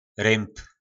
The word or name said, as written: Rymb